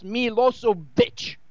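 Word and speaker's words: Milošević: It is pronounced Milosevic.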